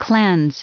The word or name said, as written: cleanse